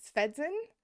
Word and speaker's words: Svendsen: I am joined by the author of Loki and Sigyn, Leah Svendsen. Did I pronounce it correctly? Because my brain told me I didn't. I hope I did. – Yeah, you got it.